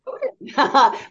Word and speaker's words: Ohden: No, but you Minnesotans really do pronounce it better than anybody else. – That's what I was thinking. Ohden. Really? – Ohden.